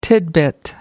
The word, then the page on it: titbit: American English